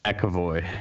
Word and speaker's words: McEvoy: McEvoy.